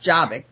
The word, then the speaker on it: Jobbik: It's not Jobbik, that's for sure.